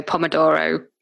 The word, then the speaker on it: Pomodoro: Is that how you pronounce it? – I think so. Pomodoro. Yeah, Pomodoro. Something like that.